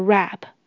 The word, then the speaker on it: wrap: 它的英文就是 vegetable wrap，vegetable wrap。请留意 w r a p 的 w 是不发音的。